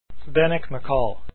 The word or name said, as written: Macal